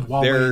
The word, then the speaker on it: Huawei: Did I pronounce that right? – Huawei.